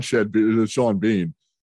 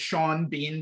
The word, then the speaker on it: Sean Bean: Does it upset you as much as it upsets me that Sean Bean doesn't rhyme because of how it's spelled? – Sean Bean?